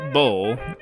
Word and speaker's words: Bole: Grega Bole, spelled B-O-L-E, but I mean, that's still how you say it, is a Slovenian professional road bicycle racer.